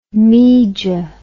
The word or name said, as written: meeja